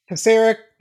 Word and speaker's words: Pisarek: David, I pronounced that wrong. – Pisarek, it's all good.